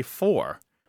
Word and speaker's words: forte: it comes from French. Mm-hmm. And in French, you don't pronounce it fort either. You could say four.